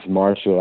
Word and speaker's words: Martial: People always mispronounce my name the way I used to. It's Martial, like M-A-R-T-I-A-L. They would make martial arts. They would think that, like, of course, yeah, it actually pronounced a different way because my father's from the Virgin Islands, so.